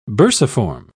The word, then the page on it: bursiform: British English